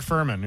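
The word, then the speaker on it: Fuhrman: Yesterday, we learned that how do you say his name? Farman? That guy from the O.J. Simpson trial. – Yeah, the cop, right. – Yeah, he died. – Mark Furman. Yeah.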